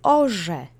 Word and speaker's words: orze: The next word is orze. Same phoneme, but this is a homonym. It's the RZ letter combination, which sounds exactly like the Ż letter in the alphabet. Orze is the third person singular of plowing. It means he, she, it is plowing or plows. The accent is on the first syllable again.